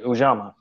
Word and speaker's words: Ujamaa: Ujamaa. Okay, I think I'm pretty sure that's it. Ujamaa. No, that's it. Ujamaa.